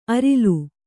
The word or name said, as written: ಅರಿಲು